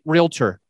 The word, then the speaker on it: realtor: When we say real estate agent, a lot of people say this, and please write it down, realtor. Just write realtor, R-E-A-L-T-O-R. Many people, it seems a very like common thing that they will have a three-syllable word. They will say realtor, realtor, realtor. It's not what it freaking is. There's no such damn thing as realtor. It's not even a word. They're making it up and they're adding a syllable, adding an I in the middle of it where it doesn't belong.